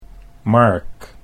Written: Mark

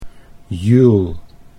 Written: Juul

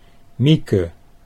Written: Mieke

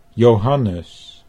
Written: Johannes

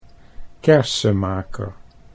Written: Kersemaeker

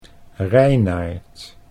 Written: Reinaart

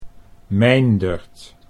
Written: Meindert

